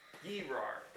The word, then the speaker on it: Gerar: Gerar. Gerar, that's what it is. Gerar, which is over here, kind of on the coast. G-E-R-A-R. It's all one word, but I separated it to help you. Gerar.